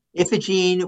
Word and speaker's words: Iphigene: Ipigene. – Iphigene was, yeah, she's the matron of the Sulzberger family.